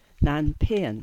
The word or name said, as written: Nanpean